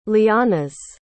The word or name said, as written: lianas